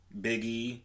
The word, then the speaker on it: Big E: Yeah, I was so pissed because for like, what, 4 years straight, he was the handstand, he was on Big E.